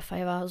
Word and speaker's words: Fiverr: Ähm, wie gesagt, F-I-V-E-R-R, ich habe das Wort Fiverr ausgesprochen.